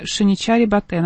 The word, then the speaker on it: Шиничари Батэ: Тогда одинокий слон по кличке Тхурбе убил родителей мужчины по имени Шиничари Батэ. Надеюсь, я правильно ставлю ударение, если что, извините. – Ты правильно прочитала.